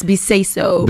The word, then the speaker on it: Beseso: You can say Bisbis. No, I'm joking. I'm not sure if my uncles will say it like that, but yeah, it's Beseso.